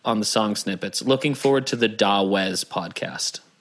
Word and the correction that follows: Dawes: I think that's pronounced Doze. – Oh, is it? – I think so. – Okay. I think it's pronounced Doze.